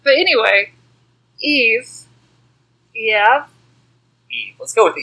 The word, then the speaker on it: Eave: it, it is E A V E. Eve.